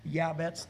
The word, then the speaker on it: Yabetz: If you're pronouncing his name in Hebrew and you would have been living during the time that he was alive, his name would have been pronounced Jabez. Yabetz. There's no J sound in the Hebrew language. There's no J in the Hebrew alphabet.